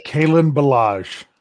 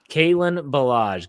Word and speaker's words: Kalen Ballage: Is it Callen or Kalen Ballage? Ballage? How do you say this guy's name? It's Kalen Ballage. – Kalen Ballage.